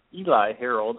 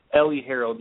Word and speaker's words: Eli Harold: Impact reports, as we looked at Melvin Gordon and Eli Harold. I don't know why I continue to say that name wrong. Ellie Harold yesterday as well.